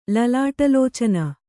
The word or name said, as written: ಲಲಾಟಲೋಚನ